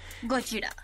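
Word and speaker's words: Gojira (ゴジラ): Y también tan solo la, pronunciación en la americana, todo es Godzilla, Godzilla, Godzilla. – Gojira. – Y Gojira, ajá, Gojira en, Japón. – En japonés, porque es el, nombre verdadero.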